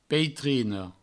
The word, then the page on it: peitrina: peitrino; provencal